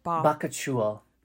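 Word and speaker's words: bakuchiol: I've kept saying bakuchi. I think I just, cause I like that it said coochie in it, but how do you say it again? – Bakuchul. – Bakuchul.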